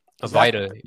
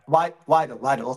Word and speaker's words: Weidel: So right here, that's, I hope I pronounced it correctly, Alice Waddle. – Weidel, yeah. – Weidel, okay.